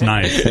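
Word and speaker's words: Gnies: Yeah, it's like G-N-I-E-S-S. It's pronounced Nice.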